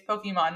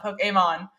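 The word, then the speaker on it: Pokémon: Like, as someone who had Pokemon cards, I'm like, it's Pokemon, not Poke-a-mon.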